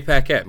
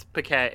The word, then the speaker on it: Paquette: What? It's Paquette. Guys, it's Paquette. It's Paquette. – Renee Ambrose. – She pronounces it Paquette.